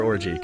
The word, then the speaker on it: Orji: And I might have to double check the pronunciation there, but it's spelled O-R-J-I, which... – Yeah, no, that's for sure orgy, confirmed.